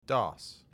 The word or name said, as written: DOS